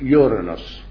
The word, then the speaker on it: Uranus: The same with the Greek Uranus, which we call, we pronounce it Uranus.